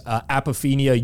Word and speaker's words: apophenia: Apophenia, you nailed it.